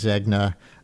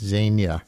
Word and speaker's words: Zegna: I was reading it phonetically as Zegna, I'm told, by people much more familiar with runways, I guess, than I am, that it's Zegna.